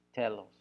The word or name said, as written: telos